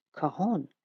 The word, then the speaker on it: cajon: I didn't know how to pronounce Cajun. That's how it's spelt. It's pronounced cajon, a type of box drum.